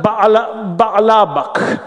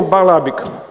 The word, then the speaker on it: Баальбек: Звучит как Баалабак, или в современном ливанском произношении Баалабик.